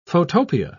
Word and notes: photopia: British English

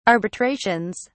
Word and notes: arbitrations: British English

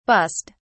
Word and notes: bussed: British English

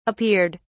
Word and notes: appeared: British English

